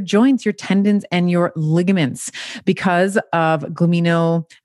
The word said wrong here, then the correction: glycosaminoglycans: So you got to, I like GAG for short, but glycosaminoglycans.